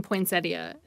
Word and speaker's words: poinsettia: Incidentally, there's no point in poinsettia, and the word is properly pronounced in four syllables, not three. Poinsettia.